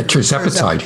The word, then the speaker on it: tirzepatide: And then also the one that's been in the news very recently, am I pronouncing this correctly, tirzepatide? – Mm-hmm. Yeah, tirzepatide.